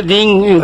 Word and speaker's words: दींग: जैसे हम दींग कहते हैं, दींग दींग तकिट तकिट, तो ये ग जो है नहीं, ये दींग दींग ग नहीं है इसमें, असल में कहने के लिए ग उच्चारण करते हैं, मगर दींग वो हुआ है दींग।